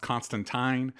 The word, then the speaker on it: Constantine: The comics go out of its way to say it's pronounced Constantine.